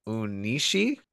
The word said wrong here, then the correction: Onishi: I think it's Takahito Onishi, Onishi? – I want to say.